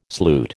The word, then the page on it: sloot: American English